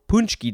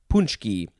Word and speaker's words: paczki: That is how you say that word, but that is not how it is spelled to my brain. Or it's spelled differently than how it's pronounced, because it's a Polish word. It is spelled P-A-C-Z-K-I. Punchki.